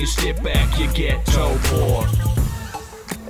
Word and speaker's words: Taupō: It's pronounced Taupō, you gotta think on your feet.